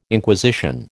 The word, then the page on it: Inquisition: American English